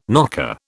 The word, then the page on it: knocker: British English